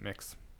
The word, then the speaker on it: Mx: Then they've got Ms. and Mix. Yeah. Or I don't know if you pronounce it Mix, but MX, which is supposed to be the gender-neutral one. – I think you pronounce it Mix.